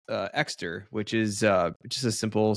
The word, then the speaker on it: Ekster: I think it's a really solid thing for me to be using, but yeah, that's called Exter wallet. Oh, it's spelled E-K-S-T-E-R.